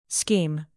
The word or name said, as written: scheme